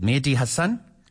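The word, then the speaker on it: Mehdi Hasan: But I came across this chat with Trevor Noah, and I think it's you pronounce his name is Mehdi. Is it Mehdi Hassan? – Correct.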